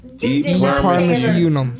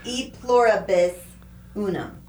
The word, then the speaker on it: E pluribus unum: E plurimus unum. – E pluribus unum. You did not say it right. E pluribus unum.